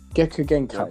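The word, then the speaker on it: Kekkei Genkai: Did I pronounce that right? Gekka Genkai. Yep. That's it.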